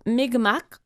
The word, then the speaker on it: Mi'kmaq: Et selon mes recherches, on prononcerait donc Mi'kmaq. Ben, le Q mm-hmm. final, c'est un son guttural dont on dit qu'il n'existe pas en français, là, mais on va dire Mi'kmaq.